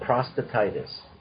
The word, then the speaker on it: prostatitis: But before we do that, I want to know how to pronounce prostatitis. Is that it? – So it's called prostatitis. – Okay, prostatitis.